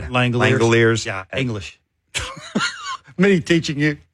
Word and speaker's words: Langeliers: His name should be pronounced Langelier. – That's not how it's pronounced. – Well, it should be. Langeliers. – That's not how you say it. – Langeliers. It should be Langelier. – Oh, yeah. – Mm. It should be. – Well, it's not.